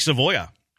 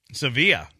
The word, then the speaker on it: Sevilla: A junkie says Sevilla junkie? – Is how it's pronounced. Almost like Savoia. Sevilla. There you go.